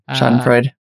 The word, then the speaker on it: Schadenfreude: The Schadenfreude, did I pronounce that correct? – Yeah, Schadenfreude.